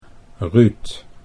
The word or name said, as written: Ruud